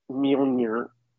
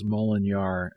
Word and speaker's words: Mjolnir: Mjolnir. – I always thought it was Molinar, and it took all these years and going to the movie theater to be schooled.